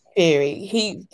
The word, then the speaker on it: Theory: But I feel like it starts to be insulting when you've got Austin Fury versus, no, is it Fury or Fury? I always say it wrong. – Theory. – Theory.